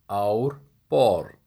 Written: Árborg

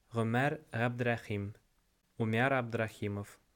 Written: Ymär Abdrahim

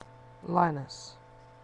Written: Pope Linus

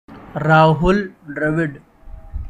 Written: Rahul Dravid

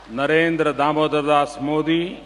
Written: Narendra Modi

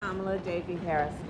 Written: Kamala Devi Harris

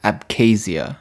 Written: Abkhazia

